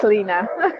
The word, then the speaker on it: Lina: Yeah, it's Lena. – Lena, and it's spelled L-I-N-A, and then you just tag the geeks onto it to make it interesting.